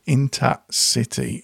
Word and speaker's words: Inta City: I'm going to take you to the end of this week's show with Inta City, and I say it that way because that's how it's spelled, I-N-T-A hyphen C-I-T-Y.